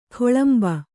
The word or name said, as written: ಗಳಿಂಬ